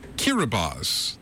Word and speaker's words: Kiribati: Kiribati, the island country of Kiribati.